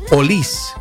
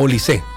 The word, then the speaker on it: Olise: Erling Haaland, la forma correcta, me fui a como la forma correcta de referirse a Michael Olise, no Olise.